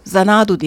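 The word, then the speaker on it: Xanadu: Zanadu diye telaffuz ediliyor ama yazılışı X ile Olivia Newton-John'dan dinliyoruz.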